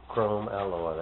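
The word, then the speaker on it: chromoly: CHRO. I can't pronounce it. – Chrome alloy, that's right. That's what it is. – Chrome alloy. – Mm-hmm. – Chrome alloy. But they put it all in one word.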